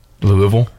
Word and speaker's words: Louisville: Louisville.